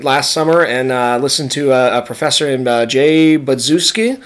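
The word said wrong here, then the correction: Budziszewski: Buzuszewski. – Thank you for correctly pronouncing his name.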